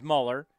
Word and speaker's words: Muller: Well, we believe how you pronounce this kid's name is Muller, M-U-L-L-E-R, M-U-L-L-E-R, Muller, like the Muller report.